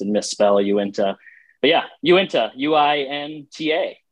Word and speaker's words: Uinta: remember it by saying Uinta beer. – Uinta beer. – Are you into beer? – Yeah.